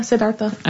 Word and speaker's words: Siddhartha: I was thinking about when they talked about Buddhism, and they said that when Siddhartha, is that how you say it? – Yeah, Siddhartha. – Okay, Siddhartha.